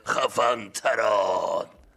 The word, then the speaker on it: خفن‌تران: این اسمی که بچه ها برام انتخاب کردن، من یه همچین اسمایی برای خودم نمیذارم، در واقع اینطوری تلفظ میشه خفنتران.